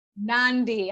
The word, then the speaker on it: Mecca Nandi: And I'm so humbled and happy to have Mecca Nandi, am I saying that correctly, to the show. – And it's Mecca Nandi. – Nandi, alhamdulillah.